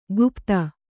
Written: ಕುಪಥ

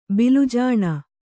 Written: ಬಿಲುಜಾಣ

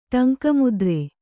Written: ಟಂಕಮುದ್ರೆ

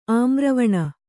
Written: ಆಮ್ರವನ

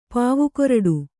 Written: ಪಾವುಕೊರಡು

